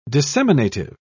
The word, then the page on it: disseminative: British English